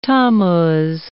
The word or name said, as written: Tammuz